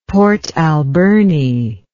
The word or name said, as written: Port Alberni